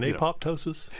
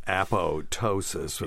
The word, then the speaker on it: apoptosis: You mean apoptosis? – Yeah. So it's just for people out there, it is spelled APOPTOSIS, but it's apoptosis when the P is silent.